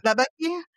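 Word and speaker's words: Labaki: lastly, I'll just mention Nadine, Labeki. I think it's Labeki. Am I saying it right? – Labeki.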